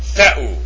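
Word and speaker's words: Theu (Θεοῦ): And it's pronounced Theu.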